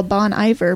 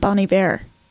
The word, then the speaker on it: Bon Iver: He was the tech for somebody who I used to call Bon Iver, but learned is actually pronounced Bon Iver.